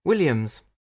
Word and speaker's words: Williams: Williams. – Could you spell that for me? – Yes, it's W-I-L-L-I-A-M-S.